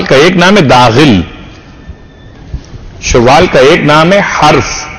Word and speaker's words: شوال: لوگ شوال بھی کہتے ہیں مگر جو اصل تلفظ و شین پر پیش بڑھا جاتا ہے شوال.